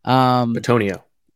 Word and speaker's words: Betonio: That said, we watched the Eagles-Browns practices, and he was in there in the starting lineup with Joel, but in place of Joel Betanio. – Betonio. – I apologize. I got to get my pronunciations down.